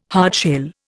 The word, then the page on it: hardshell: Indian